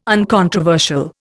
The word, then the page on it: uncontroversial: Indian